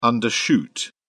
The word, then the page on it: undershoot: British English